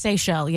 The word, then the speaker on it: Seychelles: Is it pronounced Seychelles? – Seychelles, yeah. – The Seychelles, yeah. The island, islands. – S-E-Y-C-H-E-L-L-E.